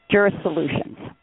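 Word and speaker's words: Jurisolutions: Jura Solutions. – Okay. It was, you better spell it. This is radio. – Okay. It's J-U-R-I-S. O-L-U-T-I-O-N-S, Solutions. So it's J-U-R-I-S, and the S is part of Solutions.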